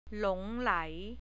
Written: lai

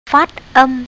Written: phatam

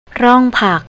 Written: ronkpak

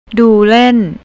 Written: dulent

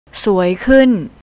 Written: suaykunt

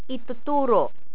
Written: ituturo

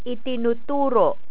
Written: itinuturo

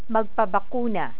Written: magpabakuna

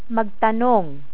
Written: magtanong

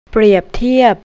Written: prepteap

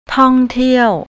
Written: tongteaw

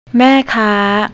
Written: maekar

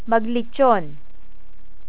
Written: maglitson

